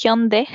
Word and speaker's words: Hyundai: Eh, o, Hyundai, en verdad es Hyundai.